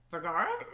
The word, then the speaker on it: Vergara: Vergara? – Excuse me? – How do you pronounce it? – Sofia Vergara, but it's Vergara. – Vergara. – For you non-Spanish speakers out there.